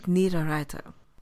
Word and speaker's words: Niederreiter: in front of Bonino is pretty good 1-2-3. It's not extraordinary. – You mean Nick Niederreiter? I'm sorry, I said it wrong. Nick Niederreiter.